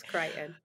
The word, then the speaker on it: Crichton: So next month, we will be reading a book by Michael Crichton. Is that how you pronounce his last name? – Yes, okay. Crichton.